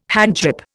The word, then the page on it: handgrip: Indian